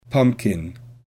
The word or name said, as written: pumpkin